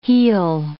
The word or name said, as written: heel